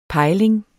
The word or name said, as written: pejling